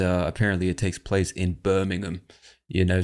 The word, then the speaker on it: Birmingham: Americans would probably say Birmingham, because that's how we say. There's a city called Birmingham in Alabama in the United States. In England, they pronounce it Birmingham, in the Midlands of England, if I'm not mistaken.